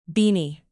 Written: beanie

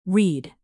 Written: reed

